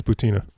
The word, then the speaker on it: Butina: I verified that it's Butina.